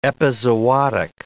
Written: epizootic

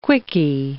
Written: quickie

